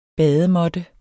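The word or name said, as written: BADEMÅTTE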